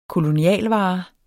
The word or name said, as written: kolonialvare